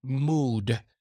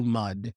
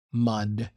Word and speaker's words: mud: He said mood, but it's actually mud. I know that the word has a U, but we pronounce it as an A, mud.